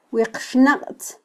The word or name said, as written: wiqxnaqtz